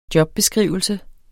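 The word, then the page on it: jobbeskrivelse: Danish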